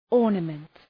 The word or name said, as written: ornament